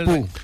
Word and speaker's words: Bu: Me han dicho que se pronuncia Pu. Se pronuncia Pu, me han dicho.